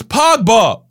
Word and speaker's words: Pogba: Yeah, it's Pogba.